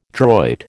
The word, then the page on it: droit: American English